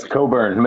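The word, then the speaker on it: Cockburn: I mean, yes, Coburn. Make sure you pronounce it. Do not sound that out.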